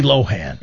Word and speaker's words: Lohan: Now, I know Mike Evans likes to pronounce it Lindsay Lohan. It's right. Lindsay Lohan. – It is. It's Lohan.